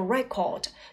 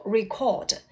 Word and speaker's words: record: 接下来呢，我们再看一看 record，表示记录，名词读作 record，动词要读作 record。